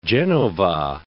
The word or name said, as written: Genova